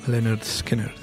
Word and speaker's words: Lynyrd Skynyrd: La cosecha del 73, como fue el primer álbum de Lynyrd Skynyrd, pronounced Lynyrd Skynyrd, ni más ni menos, ni menos ni más.